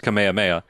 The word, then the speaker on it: Kamehameha: Yeah, the Kamehameha that I've been informed should be pronounced Kamehameha. – But they don't. – Nope. – Whatever. They don't say it that way. – They say a Kamehameha.